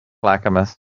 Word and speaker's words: Clackamas: Is it Clackamas? How do you say that? County? – Clackamas. – Mm-hmm. You're correct. – Clackamas County officials were laughing about what's going on.